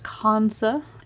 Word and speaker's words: kantha: It's pronounced kantha.